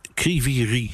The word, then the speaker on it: Kryvyi Rih: Vanuit Oekraïne even, jij zit nu in Kryvyi Rih, zeg ik het goed? – Ja, dat zeg ik uitstekend.